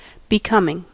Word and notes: becoming: American English

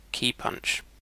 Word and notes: keypunch: British English

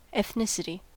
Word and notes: ethnicity: American English

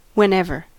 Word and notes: whenever: American English

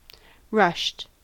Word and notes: rushed: American English

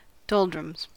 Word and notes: doldrums: American English

